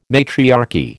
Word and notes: matriarchy: American English

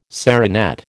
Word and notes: serinette: American English

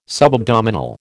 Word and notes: subabdominal: American English

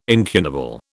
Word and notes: incunable: British English

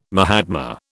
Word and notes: mahatma: British English

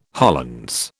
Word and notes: Hollands: British English

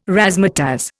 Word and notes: razzmatazz: Indian

